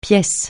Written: pièce

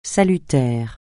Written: salutaire

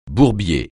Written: bourbier

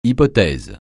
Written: hypothèse

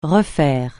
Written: refaire

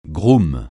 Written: groom

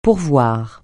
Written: pourvoir

